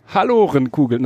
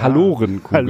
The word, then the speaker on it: Hallorenkugeln: Hallorenkugeln? Halorenkugeln. Das ist sogar richtig. Ich hatte gedacht, die spricht man Halorenkugeln aus. Ja, das klingt schon eher nach Halle an der Saale. Ja, aber ist wohl die korrekte.